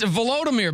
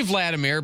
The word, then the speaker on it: Volodymyr: It's Volodymyr, by the way. Or maybe Vladimir, but maybe that's insulting because that's the Russian pronunciation.